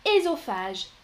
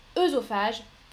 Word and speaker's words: œsophage: Donc, la prononciation classique, ce serait ésophage, estrogène, mais aujourd'hui, la majorité des gens prononcent œsophage, œstrogène.